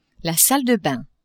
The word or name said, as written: salle de bains